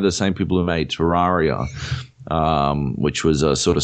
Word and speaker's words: Terraria: Terraria. – Oh, Terraria.